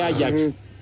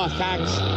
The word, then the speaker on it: Ajax: Dije Ajax, ¿eh? Dije Ajax sea, no Ajax, porque si no van a... – Pues es que, así se escribe, hombre. dije el Ajax, que es el nombre del equipo. El otro es para que, para que limpien este, los sartenes y esas cosas.